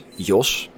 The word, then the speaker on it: Joost: So the way how to pronounce it is Jos, like a J-O-S, and yeah, that's it, a Dutch name.